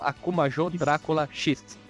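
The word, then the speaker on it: Akumajō Dracula X Chi no Rondo: É só pra não perder o costume, tenta pronunciar o nome do jogo japonês aí. É a Kumajo Drácula X Chino Rondo. Exatamente. Não, não, falei errado. O Akuma Drácula X Shinorondo é o Round of Blood. É só Akuma Joe Drácula X.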